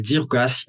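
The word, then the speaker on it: Jiro Gasy: Et donc, euh, ils ont mis euh, une société qui s'appelle DIRGAS. Euh, alors, ça s'écrit, ça s'écrit J-I-R-O espace GAS, G-A-S-Y. Alors, GAS, c'est l'abréviation pour malgache en malgache.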